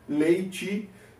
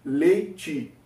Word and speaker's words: leite: Entonces, buscamos el pan, la leche o leite, en portugués es la leche, en español leichi. Recuerda la, pronuncia de la letra E al final, que en español la E se mantiene con sonido normal. Ya en el portugués cambia un poquito. Leche, leichi.